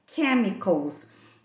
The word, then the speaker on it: chemicals: O chemicals, que sabemos que em inglês a pronúncia do CH tem som de t, mas esse chemicals é uma exceção. Então não, não pronunciamos com esse som de ti, e sim com um som de k que é que é chemicals.